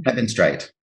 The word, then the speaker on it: Hebenstreit: Hebenstreit.